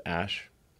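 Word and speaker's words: Æ: Um, so, I mean, it's just X, the letter X, um, and then the AE is like pronounced ash.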